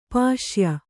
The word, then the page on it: ಪುಸ್ಯ: pusya